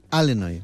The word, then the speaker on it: Illinois: Sabes que hay, como aquí en España, hay acentos diferentes en Estados Unidos, y los nativos del estado de Illinois lo pronuncian Illinois.